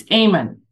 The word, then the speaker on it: Eamon: Is it Eamon? – It's Eamon. Good for you. And it's an Irish word.